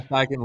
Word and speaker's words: Steichen: You know, the Philly, Shane Steichen left. Did I say that right? – Yes. – Cool. Yeah, Shane Steichen left.